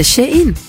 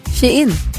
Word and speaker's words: Shein: Det heter Shein. – Shein. – Som she och in. Shein.